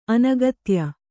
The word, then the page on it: ಅನಗತ್ಯ: anagatya